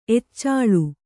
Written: ಎಚ್ಚಾಳು

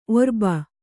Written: ಒರ್ಬ

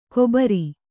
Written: ಕೊಬರಿ

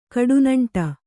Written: ಕಡುನಂಟ

